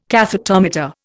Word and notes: cathetometer: Indian

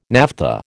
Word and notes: naphtha: American English

